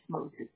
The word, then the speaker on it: Mosby: Yes, Mosby. M-O-S-B-Y, yeah. – I figured. And first name, you spell it C-A-T-H-Y, is that correct? – Yeah.